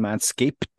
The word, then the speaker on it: Manscaped: With the ED sound at the end, Manscaped.